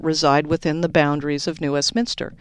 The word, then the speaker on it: New Westminster: So let's do a search there for the New Westminster Master Transportation. – Hey, you said it properly. Most people say New Westminster. – They're not from here. – I'm from here, and I often say it.